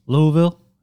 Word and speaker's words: Louisville: We're on location right now in Louisville. That's how you say it.